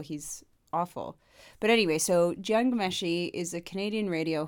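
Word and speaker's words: Jian: You pronounce it Gian. You choose to say Jan.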